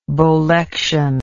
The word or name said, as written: bolectioned